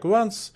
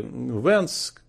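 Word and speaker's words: Vance: Ну, по крайней мере, намекнул на то, что есть такой замечательный вице-президент, как Ванс или Венс. По-разному эту фамилию произносят в России.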